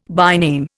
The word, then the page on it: byname: Indian